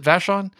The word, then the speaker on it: Vashon: Is that how you pronounce it, Vashon? – That's right.